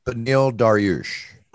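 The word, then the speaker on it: Beneil Dariush: Benil Dariush.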